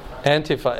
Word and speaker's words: Antifa: Antifa.